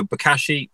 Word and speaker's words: Bokashi: And am I pronouncing Bokashi the right way? – The pronunciation is excellent, and it's one, Bokashi is the usual one that I get. But no, Bokashi, really, really simple.